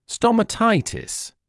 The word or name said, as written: stomatitis